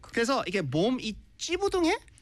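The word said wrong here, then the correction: 찌뿌둥: 이거 오늘 배웠습니다. 네. 찌뿌뚱. 찌뿌뚱. – 찌뿌뚱해요. – 찌뿌뚱해. – 찌뿌뚱해. – 네. 찌뿌뚱. 이게 이렇게 쌍이 뭐라지? 쌍지지 and 어. 쌍이 비읍부.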